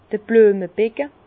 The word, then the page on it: d'r Blüemebecke: Bas-Rhin; Herrlisheim